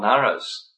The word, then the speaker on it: Nara: Therefore, the transcendental water created from the supreme Nara is also known as Nara, N-A-R-A, long A, Nara.